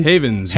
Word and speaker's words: Havens: And now we have Michael Havens. – Hello. – What's going on, man? – Hello. – Did I say that right? Havens? Haven? – Havens. – Havens, yes. – Havens with an S.